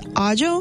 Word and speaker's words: aglio: And I've learned that garlic was written as aglio, A-G-L-I-O. So I was like, oh yeah, I'm looking for aglio. And they were looking at me like I was crazy. And I was like, aglio? It's pronounced ajo.